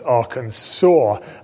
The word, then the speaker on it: Arkansas: Only later did I discover that you pronounce Arkansas Arkansas.